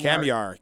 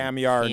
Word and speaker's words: Kamyar: Man, has he really come along as a what is it? Kamiar? – Kamiar, K-A-M-Y-A-R. – I know. I think I've been butchering that all season. – Kamiar Nouri.